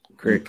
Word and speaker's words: Creek: Isn't it pronounced crick? Crick? – Crick. Crick. That's correct.